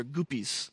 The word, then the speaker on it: goopies: They're goopies. Rhymes with groupies.